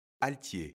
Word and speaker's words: altier: Altier. Aujourd'hui, notre mot du jour est altier, qui s'écrit IER pour le son yé.